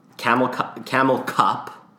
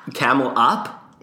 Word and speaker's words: Camel Up: Camel cup, as you put in our outline to make sure I said it right. – It's up. – Camel up? It's or camel cup? – It's camel up.